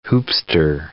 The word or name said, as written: hoopster